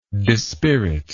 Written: disspirit

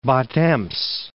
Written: Bad Ems

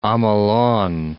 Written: Omolon

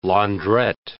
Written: laundrette

